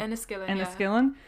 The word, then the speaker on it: Enniskillen: Inniskillen? Enniskillen. – Enniskillen.